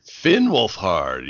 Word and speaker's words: Finn Wolfhard: Did I get his name right on that? – Finn Wolfhard, yep.